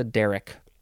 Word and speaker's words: derrick: Derrick, D-E-R-R-I-C-K, noun from circa 1752. And yes, Derrick, first syllable is emphasized.